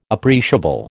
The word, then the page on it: appreciable: American English